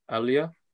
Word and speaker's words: Aliyah: I can never remember how you pronounce it. Is it Aliyah? – Aliyah.